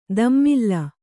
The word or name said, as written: ದಮ್ಮಿಲ್ಲ